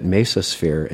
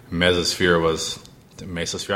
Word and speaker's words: Mesosphere: I, you know, started getting job offers were coming in, and Mesosphere. I always pronounce it wrong. I'm gonna call it Mesosphere.